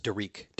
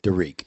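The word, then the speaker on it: Derrek: And it's, by the way, it's D-E-R-R-E-K, but it's pronounced Dariq. – Dariq, yes.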